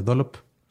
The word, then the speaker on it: Dolop: Se escribe con doble L, pero se pronuncia Dolop.